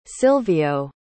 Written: Silvio

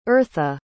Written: eartha

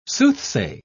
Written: soothsay

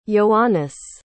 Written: ioannis

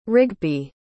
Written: rigby

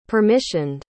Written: permissioned

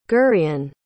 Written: gurion

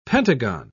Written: pentagon

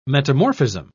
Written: metamorphism

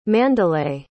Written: Mandalay

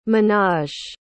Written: ménage

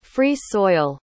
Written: free-soil

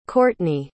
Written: cortney

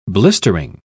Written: blistering

